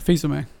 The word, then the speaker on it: Fils-Aimé: Did I say it right? Fils-Aimé or Fils-Aimé? – Fils-Aimé. – Fils-Aimé. Ah, okay.